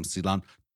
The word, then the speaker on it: cilantro: I'm like, sorry, queen, can I have some cilantro? Got to roll that R for the queen.